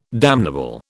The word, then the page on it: damnable: British English